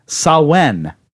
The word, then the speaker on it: Samhain: Okay, first of all, I think, although it's spelled Samhain, it's pronounced Sawen, or something along those lines."